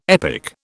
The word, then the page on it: epic: British English